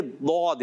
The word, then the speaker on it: ضاد: تو اس لیے کبھی بھی اس کو زواد نہیں پڑھنا. ہاں اردو میں ہمارے لفظ آتا ہے تو یہ زواد ہے کیونکہ یہ اردو کا تلفظ ہے, اہل عجم کا ہے, ٹھیک ہے نا. تو فارسی میں بھی زواد ہے مگر عربی میں یہ زواد نہیں بلکہ ضاد ہے.